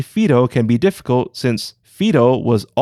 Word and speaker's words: whiro: First, Fido is spelled W-H-I-R-O, but is pronounced with an F.